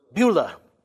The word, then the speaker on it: Beulah: How do you pronounce that? Beulah. Beulah Land.